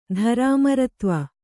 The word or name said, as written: ಧರಾಮರತ್ವ